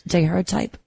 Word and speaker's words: daguerreotype: Well, in 1851, the total solar eclipse was captured for once on a daguerreotype photograph. Is that how you pronounce it? Daguerreotype? – Daguerreotype, yes.